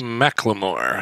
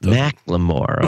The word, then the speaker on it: McLemore: Well, yeah, actually it is, but the state of the subject of S-Town, John McLemore, is that how you pronounce his name? – McLemore, I know it. – McLemore, okay.